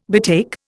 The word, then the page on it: betake: Indian